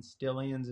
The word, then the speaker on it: Stillians: So you can just go to Instagram and you can type my name in, Caleb, with a C, and Stillians is spelled STILLIANS.